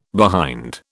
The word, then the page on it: behind: British English